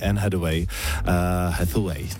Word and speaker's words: Anne Hathaway: A teď ale něco pro vás, kdo jste fanoušci americké herečky, nádherné americké herečky Anne Hathaway, takhle ji vyslovují, a filmu Deník princezny.